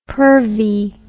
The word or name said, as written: pervy